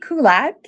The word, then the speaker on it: Kulat: So TB Kulat, K-U-L-A-T, because you can't spell my first name.